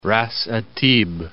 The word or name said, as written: Ra's at Tib